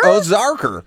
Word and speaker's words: Ozarker: Ozarker. – How do you spell that? – O-Z-A-R-K-E-R.